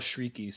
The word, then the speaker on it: Chriqui: Probably the biggest other name here is Emmanuel Chiriki, and that is spelled Emmanuel is the first name, and Chiriki, Chriqui. But I looked it up, and it's pronounced Emmanuel Chiriki, so I'm going to go with that.